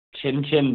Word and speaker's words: Cincin: So there's a burger place called Chin Chin Burgers. It's spelled C-I-N-C-I-N Burgers.